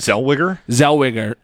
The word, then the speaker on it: Zellweger: Zellweger.